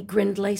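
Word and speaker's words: Grindlay: Oh, go to my website, Annie Grindley Studio, and that's spelled G-R-I-N-D as in David, L-A-Y, Studio.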